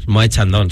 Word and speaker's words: Moët Chandon: Ayer me encontré en Instagram una story con un Moët Chandon. – Perdón. – Creo que se dice así. – Moët Chandon, sí.